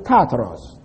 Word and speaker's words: Tartarus: The word I pronounce is Tartarus. T-A-R-T-A-R-U-S.